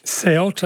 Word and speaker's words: Ceuta: Ceuta, as people are telling me, is the correct pronunciation.